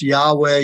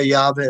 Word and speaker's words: Yahweh: As a matter of fact, the Hebrew name for God, which sometimes we see written with English letters as YHWH. Have you seen that before? – Definitely. – Sometimes pronounced Yahweh, Yahweh, etc.